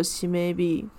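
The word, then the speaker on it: 締め日: 那因为第三天是地日，日文念作西 maybe，就是当月最终结算日。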